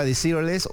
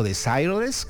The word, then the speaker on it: Desireless: Aquí estaba Desireless o Desireless, depende de si lo pronuncian en francés o en inglés.